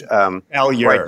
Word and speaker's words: Malheur: about the Malheur, I'm mispronouncing it because I never took French. – Malheur.